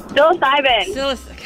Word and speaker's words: Psilocybin: I'm going to say this wrong. Psilocybin. – Psilocybin. Tell her. – Psilocybin. – Golly, I get it backwards every time.